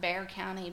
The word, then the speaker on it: Bexar: Yeah, I mean, we also have Bear County, B-E-X-A-R.